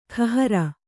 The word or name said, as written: ಕೈಹಾರೆ